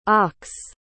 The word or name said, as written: ochs